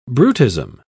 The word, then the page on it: brutism: British English